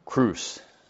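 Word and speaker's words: cruse: I've been saying it wrong. Cruise.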